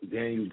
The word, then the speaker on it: Danyeil: Hit me up on Facebook, Daniel Green. My name is spelled D-A-N-Y-E-I-L, green like the color.